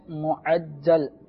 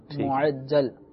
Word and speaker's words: معجل: دیکھیں, ایک ہے معجل, میم عین جیم لام, معجل.